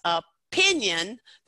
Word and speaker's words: opinion: So if you notice opinion, that we need to make sure that we put the most stress on that second syllable, opinion.